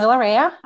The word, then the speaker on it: Valeria: Hi, uh, Tatiana Valeria. Uh, I know it's an unusual last name um, with the stress on the E.